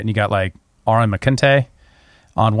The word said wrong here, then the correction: McIntyre: one side, did I say his name right? – Aaron McIntyre. – No, I don't think that's right. – His last name is McIntyre. – Oh, sure. – I thought it was McKinte. And you know,